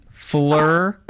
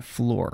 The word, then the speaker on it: Phlur: And he talked recently to a man who launched, the company is spelled P-H-L-U-R, which I wasn't sure how to pronounce. So I actually called the company to ask. – It's Fleur, not Floor. – Yes. – Fleur. – Fleur, that's correct.